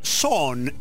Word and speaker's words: Sohn: pero su nombre artístico es Son, S-O-H-N, un nombre artístico bajo el cual tiene varios álbumes grabados se escribe S-O-H-N, repito.